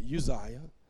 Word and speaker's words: Uzziah: But Isaiah 6:1, it says, in the year that King Uzziah died, some pronounce it Uzziah, died, I saw the Lord sitting upon a throne, high and lifted up, and the train of his robe filled the temple.